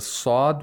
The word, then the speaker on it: ص: We have a sad with Dhamma. Sad is a letter of Isti'la. So we have to pronounce it with tafkhim. And all letters of Isti'la are pronounced with tafkhim.